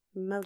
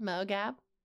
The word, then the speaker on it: Mo Gab: Yeah, it's phonetic. But people are like, how do you say that? I'm like, Mo Gab. Just like it's spelled Mo Gab.